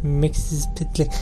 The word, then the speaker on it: مکسیز پتلک: مکسیز پتلک اسم خیلی بدی داره میدونین تلفظش همینه ولی